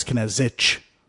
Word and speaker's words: Knezic: No H at the end either. I'm not adding H's so people know how to pronounce it. A lot of people do that. Because it's Knezic.